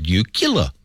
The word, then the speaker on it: nuclear: Oh, it's pronounced nuclear. It's nuclear.